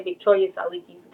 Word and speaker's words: Victoria Tsalikis: My last name is Greek, like he said, Victoria Tsalikis Greek.